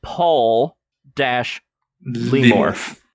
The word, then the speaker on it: Pawlymorph: This is Polymorph, and it's spelled PAWLYMORPH. – I see. – So Paul-Lemorph. – Yeah. – Oh, was it Paul or Paul? – Paul. As in dog Paul. – Wait, hold on. P-A-W? – P-A-W.